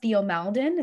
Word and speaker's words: Theo Maledon: Then moving on to, I think his name is pronounced Theo Maledon.